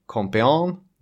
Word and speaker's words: Compiègne: Den parkerades i skogen utanför Compiègne. Det är ju de här franska uttalen. Man chansar. – Compiègne-skogen. – Compiègne, där har vi det.